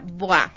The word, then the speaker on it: Bois: Sí, a ver, el centro se llama BOA, ¿no es cierto? Pero se escribe B-O-I-S. – Exactamente, ya. Se escribe Bois. – Bois. – Se pronuncia, o sea, Bois. Es en francés.